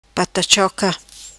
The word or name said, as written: Patachoca